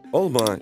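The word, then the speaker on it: алма: В татарском ударение почти всегда падает на последний слог. Да-да, прямо как у французов. «Олма» – «яблоко».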